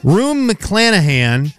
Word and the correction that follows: Rue McClanahan: I'm saying that wrong, was in what sitcom that debuted on NBC in 1985? – I'm sorry, repeat that again? – Rue McClanahan was in what sitcom that debuted on NBC in 1985? – Cheers.